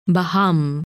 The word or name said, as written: Baham